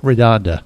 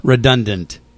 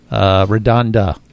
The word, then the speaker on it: Redonda: I finally figured out how to say it, Redonda. – Redundant. – Nope, that's incorrect. Redonda.